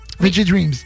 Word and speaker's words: Veggie Dreams: Veggie Dreams.